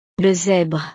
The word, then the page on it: le zebre: frances